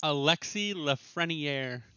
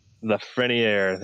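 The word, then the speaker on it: Lafrenière: But either way, the top line of him, Kirby Dack, and I'm going to butcher it, but... – Alexi Lafreniere. – Lafreniere, there we go.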